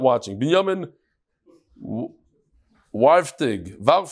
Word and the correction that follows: Warhaftig: Warhaftig. That's how you pronounce Warhaftig.